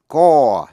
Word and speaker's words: corps: Coup, C-O-U-P, coup là cuộc đảo chính, trong đó chữ P cầm. Corps, C-O-R-P-S, corps, là một sư đoàn. The Marine Corps, sư đoàn thủy quân lục chiến. Nhớ là chữ corps, C-O-R-P-S, trong Marine Corps, P-S cầm. Thường là chữ corps, C-O-R-P-S, hay dùng ở số ít, nhưng khi sang số nhiều, corps vẫn viết là C-O-R-P-S, corps, và phát âm là corps. Phân biệt, C-O-R-P-S-E, corpse.